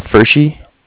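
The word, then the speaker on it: Pferschy: It's Fershi.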